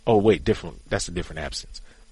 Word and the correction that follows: absinthe: Just saying. – That's absinthe. – Oh, okay. You know, it's all about pronunciation.